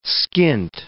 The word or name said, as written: skint